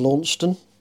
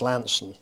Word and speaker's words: Launceston: I started work for the River Board in what you would pronounce as Launceston. – Yeah. – But it's actually pronounced Lanson.